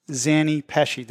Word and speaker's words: Zanni Pesce: I say Zanni Pesce, and I only say that because it's the proper Italian pronunciation.